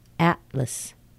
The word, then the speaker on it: atlas: And atlas. Atlas. L-A-S, but lus. Atlas. And illus. Illustration. Illus. It's an L-U-S, but it's a weak syllable.